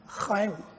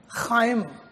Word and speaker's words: Chaim: But there is one interesting word which I want you to learn how to say it. And that's chaim. Can say that. Chaim. Yes. Chaim.